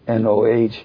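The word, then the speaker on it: Noh: When I went down to Kyoto, I went as a cinematographer, to help them photograph the NOAH drama, that's pronounced NOAH, and they were very receptive of me as a photographer, and we worked together very well.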